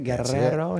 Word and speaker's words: Guerrero: Did I say that right? Guerrero? I have to roll the R.